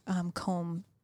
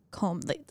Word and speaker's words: Colm: Where Colm is saying all this stuff is going on with Colm. The AI contacts Colm, pretends. Am I saying his name right? C-O-L-M. – I think it's Colm. – It's like Colm Wilkinson. Yeah. Colm, right?